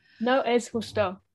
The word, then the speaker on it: No es justo: A lot of Noah's Husto. – What does that mean? Sorry, I don't speak Spanish. – It's not fair. – Okay. I agree. How do you say it? – No es justo.